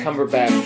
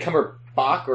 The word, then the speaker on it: Cumberbatch: I, have been a Benedict Cumberbatch fan. – Benedict Cumberbatch or Bach Batch. Batch to Benedict Cumberbatch. Batch. – It's not. It's not spelled. It's not pronounced like Bach.